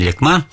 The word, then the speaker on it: Лекма: это родники вдоль реки Лекма. Правильное ударение — Лекма.